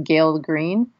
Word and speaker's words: Gael Greene: It's called Insatiable and it's the a nonfiction book by I'm probably going to butcher her name, but as far as I know, it's pronounced Gael Greene.